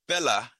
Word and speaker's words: Fela: And if you are not familiar with, well, see, I have the American pronunciation. My Nigerian comrade said it's Fela.